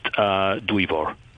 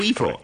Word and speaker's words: Dwyfor: It's close, but not 100% correct. I'll correct you if you want. Two simple syllables. It's pronounced Dwyfor. – Dwyfor. – Yes, Dwyfor.